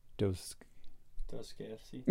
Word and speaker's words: Dostoevsky: And Sarah loves music, and that the quote, beauty will save the world by, I can never pronounce his name, Dostoevsky.